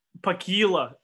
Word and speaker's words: Pakila: Pakila. Anytime there's this like A with the doubles. – Yeah. – The A double like that.